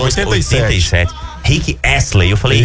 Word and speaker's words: Rick Astley: Não é Rick Staley, não. É Rick Astley.